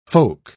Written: folk

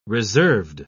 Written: reserved

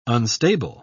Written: unstable

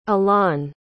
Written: alain

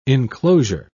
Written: enclosure

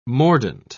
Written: mordant

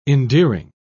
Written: endearing

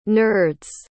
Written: nerds

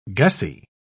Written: gussie

